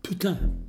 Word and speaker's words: putain: Uttala det på franska för det betyder hora. På franska, ja. Putain.